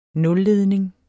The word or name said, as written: nulledning